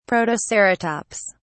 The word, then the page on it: protoceratops: British English